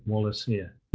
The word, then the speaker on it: Wallacea: I had difficulty knowing how to pronounce this, and I'm told that the organization in themselves pronounce it Wallacea.